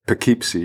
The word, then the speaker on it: Poughkeepsie: In die eerste week, eh, want we gaan naar Poughkeepsie. – Oh, eh, spreek je het zo uit? – Poughkeepsie, ja.